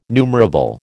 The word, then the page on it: numerable: American English